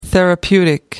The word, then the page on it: therapeutic: American English